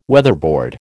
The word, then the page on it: weatherboard: American English